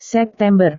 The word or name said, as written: september